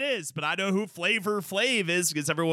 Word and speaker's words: Flavor Flav: Everyone wants, and even the article I recently read his name in, it's not Flava Flav, it's Flavor Flav. It's O-R, not A.